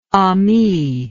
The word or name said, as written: amie